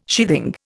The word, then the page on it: sheathing: Indian